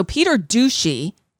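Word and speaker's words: Peter Doocy: So Peter Ducey. That's how his name should be pronounced.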